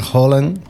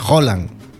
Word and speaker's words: Haaland: yo que sé, por ejemplo, S-O-A-K-J, ¿cómo pronuncio yo eso? – Ya, claro. No, pero lo digo que Haaland, las dos A se pronuncian como O. – Vale, ya, sí. – Entonces se pronuncia Haaland, en realidad.